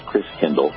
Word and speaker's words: Christkindl: The German pronunciation of Christ child is Christkindl or Christingle.